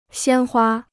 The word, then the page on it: 鲜花: xiān huā